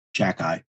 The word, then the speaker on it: Xhekaj: But as you said, for those keeping score at home, his last name is spelt X-H-E-K-A-J and it's pronounced Jack Eye. And if you spell it backwards, it looks more phonetically correct than if you spell it properly.